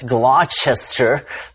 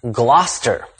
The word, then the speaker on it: Gloucester: The uninitiated might be tempted to pronounce it as Gloucester, but actually it's Gloucester.